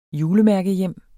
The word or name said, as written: julemærkehjem